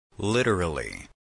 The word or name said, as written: literally